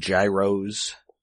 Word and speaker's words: gyros: Anyway, so yeah, they're gonna head over to Greece, I don't know, find some ancient artifacts, maybe get some gyros. – Mm-hmm. – Hey, you pronounced it properly. – Oh. Speaking of things that also sound like robot parts, gyros.